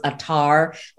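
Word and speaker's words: attar: Atharwalas, you know, in the English, it's pronounced Atar, like rose Atar, okay, this Atar, that Atar.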